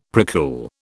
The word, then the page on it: precool: British English